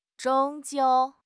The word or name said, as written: 终究